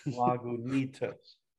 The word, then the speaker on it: Lagunitas: Lagunitas.